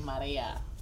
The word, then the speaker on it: Maria: I'm going to call you Maria. – Maria. – Guadalupe. – You got to roll your R.